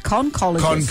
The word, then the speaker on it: conchologist: Conch was the giveaway, but it's pronounced conchologist.